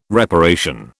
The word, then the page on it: reparation: British English